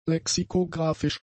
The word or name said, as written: lexikographisch